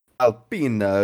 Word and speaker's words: Alpine: Alpina, which is the French pronunciation of a French car brand who is also a constructor in F1, will have a podium finish at some point in time this year.